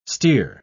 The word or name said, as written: steer